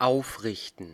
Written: rear

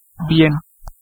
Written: very

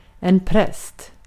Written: minister

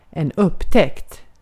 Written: discovery

